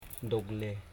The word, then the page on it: dogle: dog.le